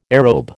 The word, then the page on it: aerobe: American English